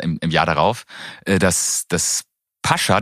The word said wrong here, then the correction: Pacha: dass man übrigens Pacha ausspricht. Entschuldigung. – Learning Nummer eins ja weiß niemand. Pascha. Ich habe das auch mal Pascha ausgesprochen, aber in, da es in Spanien äh, gegründet wurde, spricht man das dort immer nur Pacha aus.